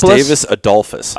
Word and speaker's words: Gustavus Adolphus: Now, she's a senior this year, and she's a Gustavus, am I saying it right, Adopolis? – Gustavus Adolphus. – Adolphus. Commit. Gustavus Adolphus.